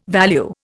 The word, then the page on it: value: Indian